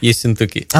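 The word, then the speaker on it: Yessentuki: And we're just about to welcome our guest who comes from Yesentuki. Is that actually how you pronounce it? – Yesentuki.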